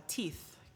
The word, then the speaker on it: Teith: we called her Teith, Tiffy and Keith. – Teith. That's cute. – Oh, I just got that now. – Yeah. So it's spelled like Keith with a T. – Yeah. – T-E-I-T-H.